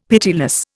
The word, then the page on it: pitiless: Indian